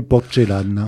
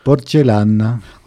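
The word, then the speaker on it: porcellana: Bah, à l'origine, porcelana, je ne sais pas prononcer moi, l'italien, c'est pas mon truc, c'est porcelana qu'on dit ? – Oui, porcelana.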